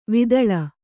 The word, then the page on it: ವಿತಾಳ: vitāḷa